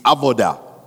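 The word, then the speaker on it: avoda (עבודה): So everything that just doesn't make sense when you look at that word, but the, the pronunciation of the word is avoda.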